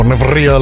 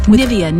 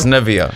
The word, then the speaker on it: Nivea: You found what the official way or pronunciation is this morning? – Yeah, that's right. I found an ad, and here it is. – Okay. – With Nivea Nourishing Body Milk. – Wait, do it one more time. – The G. – With Nivea Nourishing Body Milk. – Yeah, so it's Nivea.